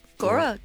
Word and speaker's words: Gaura: And another one, I know you're going to tell me off for my pronunciation of this, is Gaura. Gaura. Gaura. G-A-U-R-A. Gaura. – G-A-U-R-A. Oh, mm-hmm. Yeah. Well, like Laura. Gaura. Gora, Gora, Gora, Gora.